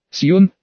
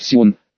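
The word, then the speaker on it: Sion: Сьон, гора и одноименный город в Швейцарии. По-настоящему нужно произносить Сион, но было решено не конкурировать с настоящей горой Сион в Иерусалиме.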